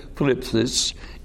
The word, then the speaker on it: thlipsis: The first word is the word thlipsis, and that's pronounced correctly, thlipsis, and it literally means tribulation, affliction, trouble, anguish, persecution, and being burdened.